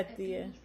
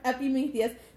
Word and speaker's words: Epimetheus: Epimetheus. Yep, I said it right. Epimetheus.